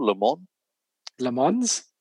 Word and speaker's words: Le Mans: Le Mans is how it's pronounced.